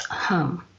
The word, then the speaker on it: HAM: The mantra for the heart chakra is H-A-M, pronounced hum. And during meditation, it's drawn out like this. Hum.